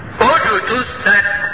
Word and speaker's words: اجتثت: ومثل كلمة خبيثة كشجرة خبيثة اجتثت. اجتثت. سمع نطق بتاعي? ما جيش واحد يطال يقول اجتثت. بالله عليك تنفع تتقلقل? تنفعش تتقلقل والله. لكن عشان تبقى تتقلقل اجتثت من فوق الارض.